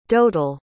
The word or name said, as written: dotal